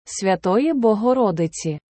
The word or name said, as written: Святої Богородиці